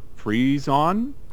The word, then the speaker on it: frisson: It's called frisson. Frisson is probably the best way. So it's F-R-I-S-S-O-N.